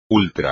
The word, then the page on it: ultra: espagnol